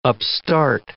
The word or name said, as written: upstart